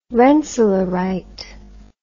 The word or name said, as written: rensselaerite